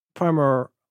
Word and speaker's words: Primer: The person who made the movie, the aforementioned terrible person, gave up on trying to get people to say Primer. So now officially it is called Primer because that's what everybody was calling it, which is a bummer for pronunciation, but it is what it is.